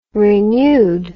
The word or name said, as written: renewed